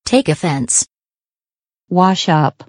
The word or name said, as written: take offense